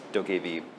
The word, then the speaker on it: Dokev: It's spelled D-O-K-E-V, pronounced Dokkv.